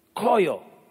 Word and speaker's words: chayah: It's not spelled the way it sounds. It's spelled C-H-A-Y-A-H. Koyo.